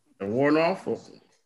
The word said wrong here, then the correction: Warnock: Did I say that right? – No. Nope. – You know you didn't. – Where's that sound? This magic moment. – Who was it? Warnock. – Warnock, yeah. – Yeah, Warnock.